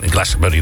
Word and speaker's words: Glastonbury: Dat vindt altijd in Glastonbury natuurlijk plaats.